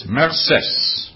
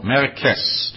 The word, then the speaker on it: merces: Also, both the word mercy and merci go back to a Latin word, which is merces, or merces, depending on how you pronounce it, M-E-R-C-E-S.